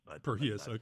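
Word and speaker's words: Prohías: You took over from Antonio Perhias, or I've never known how to pronounce his last name. – Perhias.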